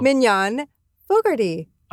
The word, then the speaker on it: Mignon Fogarty: Mignon Fogarty.